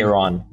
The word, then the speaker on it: Yaron: Yeah, you can Yaron, just say Yaron.